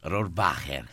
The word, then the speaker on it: Rohrwacher: Rohrwacher, con un po' di aspirata. – Però lei è nata in Italia, si può dire anche Alba Rohrwacher, e Alba, che è una donna straordinaria, non se la prende.